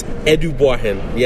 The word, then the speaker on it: Edubuahen: It's Edubuahen. Yes, it is. It sounds nothing like how it's spelled, so don't feel bad if you can't pronounce it.